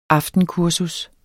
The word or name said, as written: aftenkursus